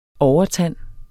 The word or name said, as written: OVERTAND